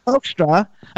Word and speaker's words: Hoekstra: How do you pronounce his name? Hoekstra? – Hoekstra.